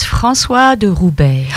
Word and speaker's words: François de Roubaix: That was François de Roubaix.